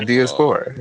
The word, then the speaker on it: Diaspora: Diaspora.